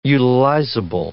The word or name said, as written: utilizable